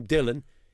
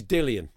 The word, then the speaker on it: Dillian: And by the way, one thing we did establish, even though Anthony Joshua calls him Dillon, he's Dillian.